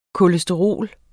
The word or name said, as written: kolesterol